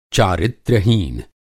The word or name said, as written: चारित्र्यहीन